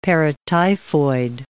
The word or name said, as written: paratyphoid